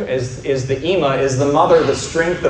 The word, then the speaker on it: ima: It's the word for mother, ima.